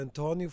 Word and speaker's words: António: Mein Name ist Antonio oder Antonio Fernandes Coelho auf Portugiesisch ausgesprochen.